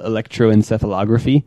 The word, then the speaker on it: electroencephalography: So, we can use this thing called EEG, electroencephalography, which they basically put... – You pronounced it right.